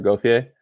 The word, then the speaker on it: Gauthier: With Gauthier or whatever. – It's Cutter Gauthier. – Gauthier, thank you.